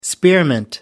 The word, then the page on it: spearmint: American English